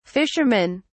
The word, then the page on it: fishermen: British English